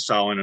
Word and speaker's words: Samhain: And it's spelled S-A-M-H-A-I-N, just right, to confuse us Americans. And that's not really why. But in the old Gaelic language, it was said, it was pronounced Samhain.